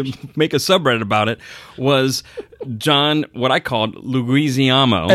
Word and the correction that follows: Leguizamo: The correct pronunciation is John Leguizamo. – Yeah, I say it Leguizamo.